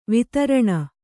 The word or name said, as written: ವೈತರಣಿ